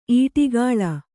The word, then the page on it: ಈಟಿಗಾಳ: īṭigāḷa